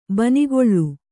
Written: ಬೆಣ್ಣೆಗಳೆ

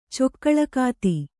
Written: ಚೊಕ್ಕಳಕಾತಿ

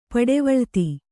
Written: ಪಡವಳಿತಿ

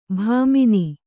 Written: ಭಾಮಿನಿ

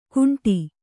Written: ಕುಂಟಿ

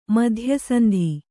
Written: ಮಧ್ಯಸಂಧಿ

